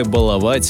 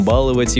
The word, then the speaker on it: баловать: Ударение в слове «баловать» скоро изменится, рассказал филолог Владимир Пахомов. Очевидно, что будущее за ударением «баловать» и «баловаться», подчеркнул научный сотрудник Института русского языка имени Виноградова. Пахомов пояснил, что в орфоэпическом словаре русского языка варианты «баловать» и «баловать» пока фиксируются как равноправные.